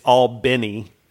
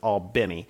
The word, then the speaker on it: Albany: So, and I need to say it, it's Albany is how locals say it, is Albany.